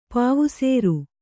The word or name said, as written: ಪಾವುಸೇರು